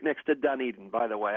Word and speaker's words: Dunedin: I wanted to give you points for pronouncing it correctly before with your Blue Jays. – Yes. – For some reason, down here, they often call it Dunedin.